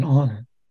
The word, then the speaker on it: honor: I was mentioning the word honor and it's so interesting that the H is silent in honor.